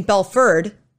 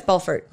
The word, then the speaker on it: Belfort: So I noticed you pronounced the T. – You are supposed to say the T. – It is? – I'm pretty sure. – I never known. I thought it was like French. Or you got to say Belfort? – Well, for a long time, I thought they were saying Belford. – But it's Belfort? – Yeah, it's Belfort.